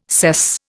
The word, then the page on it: sess: Indian